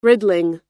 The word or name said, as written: riddling